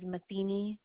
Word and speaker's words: Matheny: Last name is Matheny, M-A, T as in Tom, H as in Henry, E-N-Y.